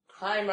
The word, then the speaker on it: Haim: Haim rhymes with time.